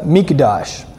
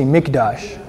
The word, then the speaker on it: mikdash: It's a mikdash. Everybody say mikdash. And you spell that M-I-K. In English you spell it M-I-K. D-A-S-H. Mikdash.